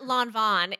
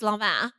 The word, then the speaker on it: Lanvin: L-A-N-V-I-N, right? It's right. Not Lanvin, it's Lanvin.